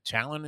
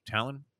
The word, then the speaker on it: Taillon: They're going to throw Cortes in a game this afternoon where they were going to have to go uh, with, uh, is it Talon? Is that how you pronounce the guy's name? Talon? T A I L L I O N? T A I L L O N. Talon? Is that how we pronounce this guy's sure.